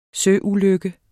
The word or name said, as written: søulykke